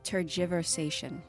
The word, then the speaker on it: tergiversation: Tergiversation. Am I saying it wrong? Will you keep repeating me? – I initially said tergiversation, but it's tergiversation. So you were right in everything except for the G.